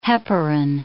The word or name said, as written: heparin